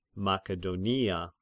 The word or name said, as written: Makedonia